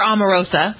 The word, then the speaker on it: Omarosa: Amoroso, one of the... – Or Omarosa.